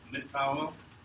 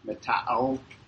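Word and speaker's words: Mata-o (ματαιόω): Mata-i-o. Pronounce it for me. That's a hard one for me. I don't know why. It's got too many vowels in there. Say it. – Mata-o. – Okay. Mata-o. Empty, worthless.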